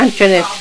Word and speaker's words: Antoinette: Yeah, Antoinette Woodson. Uh-huh. – How do you spell it? – A-N-T-O-I-N-E-T-T-E.